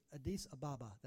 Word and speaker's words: Addis Ababa: And in 2008, God called him to go plant Bethel International Church in the capital city, Addis Ababa.